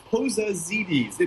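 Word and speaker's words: Pozadzides: It's Pozazides. If you can ignore that first D, it's pretty much just the way it's spelled, although we owe that spelling to the immigration officers, believe it or not.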